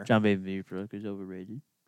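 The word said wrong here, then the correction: Vanbiesbrouck: John Van Beesbrook.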